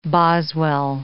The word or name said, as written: Boswell